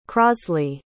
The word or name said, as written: crosley